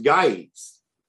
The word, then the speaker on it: Gyges: So a G in Greek that is followed with an E or an I goes to a Y sound. – Mm. – so like- it should be pronounced guides right?